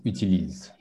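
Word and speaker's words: utilisent: So remember, it's utilise, it's ENT, but it's pronounced utilise.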